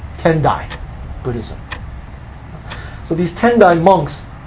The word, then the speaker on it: Tendai: They pronounce it, instead of Tiantai, they pronounced it Tendai.